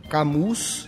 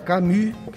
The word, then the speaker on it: Camus: Camus é menos conhecido, escreve Camus, né, em francês. Albert. Albert Camus, que se pronuncia, mas é Camus. Eu falo Camus, que é mais sem pronúncia muito rebuscada francesa autor de um clássico da literatura francesa do século XX, que é O Estrangeiro, um livro magnífico.